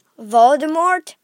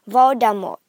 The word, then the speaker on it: Voldemort: It's uh Everyone's been pronouncing it Voldemort, but it's supposed to be Voldemort. The T is the T is uh, silent, and the D Voldemort. It's not D E R. It's D A H.